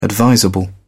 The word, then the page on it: advisable: British English